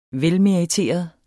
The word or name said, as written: velmeriteret